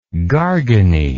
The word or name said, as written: garganey